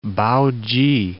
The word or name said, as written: Pao-chi